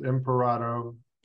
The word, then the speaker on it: Imparato: My last name is pronounced Imparato. – Imparato. So I'm sorry. – It rhymes with desperado.